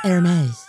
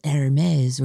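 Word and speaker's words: Hermès: Didn't you just take a whole tutorial on how to Hermes? The guy was very good. He gave you two different pronunciations. Well, depending on your accent, you might want to go Hermes, or you could go like Ernest, Hermes.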